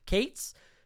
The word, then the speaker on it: Cates: Michael Katz. Cates? We have Daryl Cates here in the city. It's spelled the same way. So you're getting pronounced Cates.